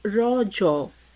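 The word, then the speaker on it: Raja: The festival is natively pronounced as Rojo, a local term that means menstruating woman.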